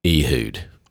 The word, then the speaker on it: Ehud: It's spelled E-H-U-D. So it could be Ehud, but that sounds weird. – Yeah, who wants to say Ehud? Get over here. – Yeah, Ehud. – And besides that, Ehud rhymes with dude, and I like that.